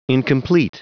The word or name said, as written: incomplete